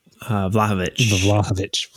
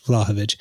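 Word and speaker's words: Vlahović: I think one of the big issues we're having with signing a Vlahovic, is that how you say it? – Vlahovic. – Vlahovic.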